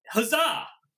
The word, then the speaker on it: Huzzah: How would you pronounce that? H-U-Z-Z-A-H. – I was pronouncing Huzzah! Huzzah. – Yeah. That's how you pronounce that one.